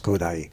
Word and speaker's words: Kodály: That's Kodály. But the point is, the accent is on the first syllable.